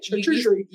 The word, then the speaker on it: charcuterie: Or if you make a charcuterie board, which I said it's not right, charcuterie. – You said it right.